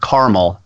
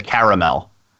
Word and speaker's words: caramel: And there are two syllables in the word caramel as opposed to caramel.